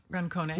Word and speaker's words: Rancone: Margaret Racone... Is it Racone? – Rancone. – Rancone. – Yeah. – Is it Italian? – An Italian man told me that's really? – How you pronounce it.